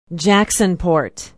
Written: jacksonport

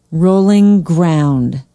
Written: rolling ground

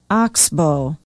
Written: oxbo

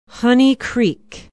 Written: honey creek

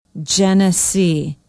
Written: Genesee

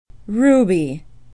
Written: rube

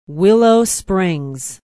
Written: willow springs

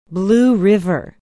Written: blue river

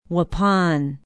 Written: waupun

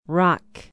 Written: rock

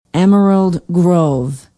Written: emerald grove

